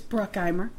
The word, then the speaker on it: Bruckheimer: Well, that's one extra syllable. – I like to put the German. – One extra syllable. It's Bruckheimer.